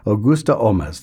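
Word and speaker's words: Holmès: Here's something conducted by Nikolai Shep-Snyder, music of Augusta Olmes, whose heritage was Irish, and she changed her name later in her life to become more French. Thus the pronunciation, Augusta Olmes.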